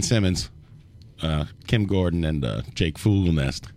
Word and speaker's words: Fogelnest: It's Fogelnest.